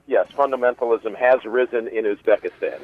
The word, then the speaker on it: Uzbekistan: So that's the terrorist in question here came under that program in 2010 from Uzbekistan, is that how you pronounce it? – Mm-hmm. Correct. Uzbekistan, yeah. – Uzbekistan.